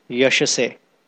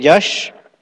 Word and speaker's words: Yasha: Then, a very nice phrase here is Mrishta Yashase. Yasha, we sing in this Guru Vandana song every morning. Ebe Jash Ghushuk Tribhuvana. Although we say Ebe Jash, because in Bengali sometimes Ya is pronounced as Ja, like in Spanish.